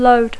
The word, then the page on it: load: English